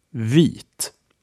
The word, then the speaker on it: vit: A Swedish example is vit, meaning white. Say after me, vit.